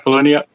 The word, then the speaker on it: Palahniuk: Of course, this movie is based on the novel Fight Club by Chuck Palahniuk. Is that how you say his name? I think we'll go with it. – Yep. – Chuck Palahniuk.